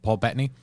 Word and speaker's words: Paul Bettany: Paul Bettany.